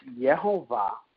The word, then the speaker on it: Yehovah: Remember, how we have Jehovah and all those, but in Hebrew it's Yehovah, and no Jewish person can pronounce Yehovah.